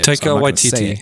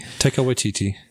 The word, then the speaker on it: Taika Waititi: In the scene of Jojo Rabbit 2019, Hail Hitler is said 31 times in 1 minute, according to writer, director, I'm going to butcher that name. – Taika Waititi. Taika Waititi. – Yeah, there you go.